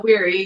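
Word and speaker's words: Wehry: The father of her child, Nick Weary. Am I saying his name right? – Weary, yeah.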